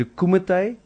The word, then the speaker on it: kumite: Is it a kumite? – Yes.